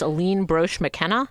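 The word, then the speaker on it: Aline Brosh McKenna: It was created by Rachel Bloom and, help me out, it's Aline Broche McKenna? – That's right.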